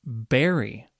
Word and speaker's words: bury: Berry. Yes, this has the same pronunciation as berry, which is a type of fruit. Berry, B-U-R-Y, berry, has a very different meaning, though.